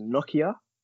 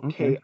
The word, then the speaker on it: Nokiaa: I think his name is pronounced Nokia. Nokia.